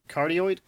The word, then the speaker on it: cardioid: Yeah, it's a cardioid. Cardioid is how you say it.